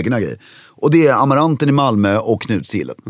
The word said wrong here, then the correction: Knutsgillet: Lite felbetoning där. Knutsgillet, ja, hur nu heter.